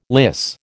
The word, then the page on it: lyse: American English